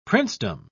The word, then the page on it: princedom: British English